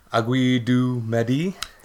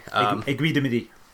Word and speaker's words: Aiguille du Midi: Actually was nice enough to take me out onto the Aiguille du Midi today. – That's the best pronunciation I've ever heard from an American, honestly.